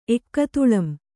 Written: ಎಕ್ಕತುಳಂ

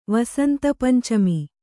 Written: ವಸಂತಪಂಚಮಿ

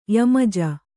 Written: ಯಮಜ

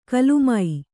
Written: ಕುಲಿಮೆ

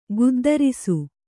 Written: ಗುದ್ದರಿಸು

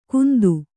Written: ಗುಂತು

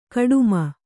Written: ಕೋಟೆಮನೆ

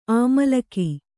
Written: ಅಮ್ಲಿಕೆ

